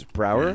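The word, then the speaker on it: Braugher: Yo, rest in peace, Andre Brogner. – Yeah. – Or Andre Brower, is it? – Andre Brower. – I don't know how to pronounce that, man. It's Brower? Yeah.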